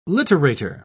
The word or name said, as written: literator